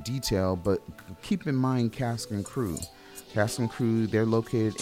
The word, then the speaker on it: Cast and Cru: Again, Cask and Crew. And Cask and Crew is spelled Cast and C-R-U.